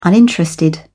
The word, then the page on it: uninterested: British English